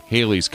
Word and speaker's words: Halley: English astronomer Edmund Halley, because we do say Halley's Comet, but I believe the pronunciation of his name is actually Halley.